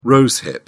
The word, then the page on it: rose hip: British English